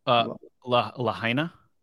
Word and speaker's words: Lahaina: Lahaina. – Lahaina.